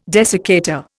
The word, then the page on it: desiccator: Indian